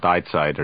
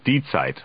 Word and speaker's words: Die Zeit: It's not Die Zeit, it's Die Zeit, according to this individual.